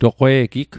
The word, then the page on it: toqwe'gig: Mi'gmaq